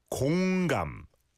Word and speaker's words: 공감: Again, almost sounds like 건강, but it's 공감, 공감.